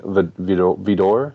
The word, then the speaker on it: Vidor: It's directed by King Vidor.